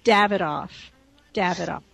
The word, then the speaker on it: Davidoff: So sure enough, I go the second day, and I pronounce it the British way, and I get right in. So the British way, in case you're ever in London, is Davidoff.